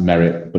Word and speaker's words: Maret: There's also someone called Marae, or it's spelled Naret, but a name, you pronounce it Meret, or Merit, sorry. So pronounced Merit, but it's spelled Maret.